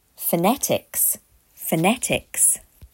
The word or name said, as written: Phonetics